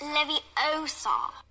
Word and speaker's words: Leviosa: Besides, you're saying it wrong. It's Leviosa.